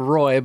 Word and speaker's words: Roy: I don't remember exactly why, but there's a video of a famous video of a goalie named Patrick Roy, which is spelled R-O-Y. It's spelled Roy, but it's pronounced Roy because it's French.